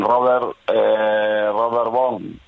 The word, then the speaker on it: Robert Vaughn: Con Robert Vaughn, no sé si lo pronuncio bien.